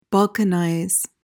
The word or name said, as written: balkanize